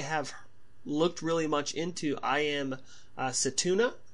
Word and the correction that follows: Setsuna: is that am I saying that correctly? Setsuna, yeah. yeah.